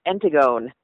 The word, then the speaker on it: Antigone: Antigone.